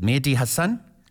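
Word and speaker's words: Mehdi Hasan: Is it Mehdi Hassan? – Correct. Yeah.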